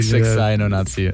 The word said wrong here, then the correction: Niju Roku Sai no Natsu Yasumi: One more time. I want to say it right. – So it's Niju Roku Sai no Natsu Yasumi.